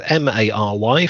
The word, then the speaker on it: Mary: And I did look up the pronunciation. It's M A R Y. From what I can work out, it's pronounced Mary.